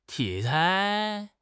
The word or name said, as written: 题材